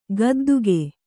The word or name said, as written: ಗುದ್ದಿಕೆ